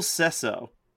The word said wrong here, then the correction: Sasso: Will from Sesso. Mad TV. – Oh, Will Sasso. Is that how you say Sasso? Yeah. Is Sasso his the way you say his name? – I think it's Sasso. – It's probably Sasso.